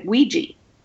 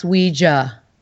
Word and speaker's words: Ouija: so maybe that's why it's always been Ouija. But that's not correct, yeah. I understand. – Well, really now you could say it anyway, Ouija or Ouija, but it's truly pronounced Ouija if you want to use the real pronunciation.